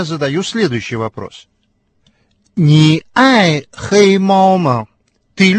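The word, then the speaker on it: хэй (黑): Прилагательное «хэй» произносится с первым тоном.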